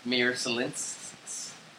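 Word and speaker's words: Merzļikins: At the service on Thursday, his Columbus teammates and fellow goalie Elvis Mirsalinskis, that's how it's pronounced phonetically, said his friend died a hero, saving other people, saving Mirsalinskis and his pregnant wife that night.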